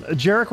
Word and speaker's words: Jerick: Welcome, Jerick Robbins. I hope I said that right. It's just Jerick like Derek, right? – You nailed it. – All right.